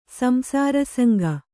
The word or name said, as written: ಸಂಸಾರಸಂಗ